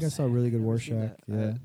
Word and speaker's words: Rorschach: And then I wanted to be, let's see if I pronounce this right, Warshek. – Rorschach? – From Watchmen.